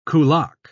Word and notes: kulak: British English

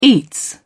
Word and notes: eats: British English

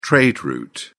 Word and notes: trade route: British English

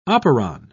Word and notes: operon: British English

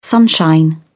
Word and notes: sunshine: British English

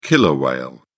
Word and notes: killer whale: British English

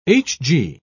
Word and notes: hg: British English

